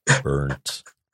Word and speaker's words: burnt: Phil, in this context, you have to really pronounce the R. Burnt. Because burnt. Burnt, please. Please emphasize the R.